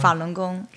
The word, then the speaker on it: Falun Gong: Falun Gong, So I guess that's kind of like a side sect, not like a big thing in China, but like a small part. – Falun Gong is more or less like a propaganda thing.